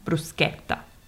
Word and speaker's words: bruschetta: Yeah, so here we get there's a CH, so we pronounce it as a hard K sound, bruschetta.